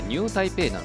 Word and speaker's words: 新北: 新しい北と書いてニュータイペイ